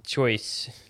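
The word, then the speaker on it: Chios: You just pronounce K-I-O-S? – Choice. – K-I-O-S.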